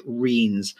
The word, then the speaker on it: rhynes: And there are a number of drainage ditches, which in Somerset we call reens. It's spelled R-H-Y-N-E-S, but pronounced reens.